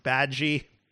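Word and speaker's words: Badguy: It's pronounced Badgie.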